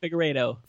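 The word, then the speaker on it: Figueredo: Mike Figurado. Did I say that right? – Figurado. – Fuck. Mike Figurado. – That's close enough, man. I'll give it to you.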